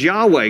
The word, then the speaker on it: Yahweh: And in Hebrew, it is just four letters, YHWH. And when we insert vowels to try to pronounce it, it is Yahweh.